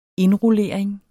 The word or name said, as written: indrullering